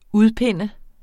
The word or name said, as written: udpinde